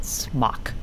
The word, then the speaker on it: SMOC: or I guess maybe they would pronounce it smock.